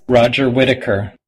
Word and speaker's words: Roger Whittaker: Roger Whittaker.